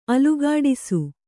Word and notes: ಅಲುಗಾಡಿಸು: Kannada

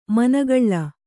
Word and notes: ಮೊನೆಗೊಳ್ಳು: monegoḷḷu

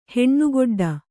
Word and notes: ಹೆಣ್ಣುಗೊಡ್ಡ: heṇṇu goḍḍa

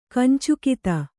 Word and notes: ಕೊಂಚಗಾತಿ: koncagāti

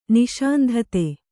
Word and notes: ನಿಸ್ಸಂತತಿ: nissantati

